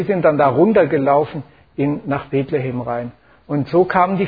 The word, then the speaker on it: Bethlehem: Bethlehem, da so spricht man es Hebräisch, so sieht das aus. Heißt eigentlich Bethlehem.